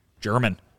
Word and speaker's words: German: So, obviously with a lot of players, there's a pronunciation guide that comes along with their name when they are acquired. And, hey, right there in the press release when the Sox made that deal for the former Red Sox pitching prospect, there it was, pronunciation guide, German.